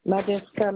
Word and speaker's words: Madinska: Madeska Marie-Chantal. – Ça s'écrit comment, s'il vous plaît? – M-A-D-I-N-S-K-A.